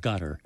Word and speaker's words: Qatar: I had an old friend, elderly friend, who worked in the area who said that is actually how they pronounce it, gutter, not...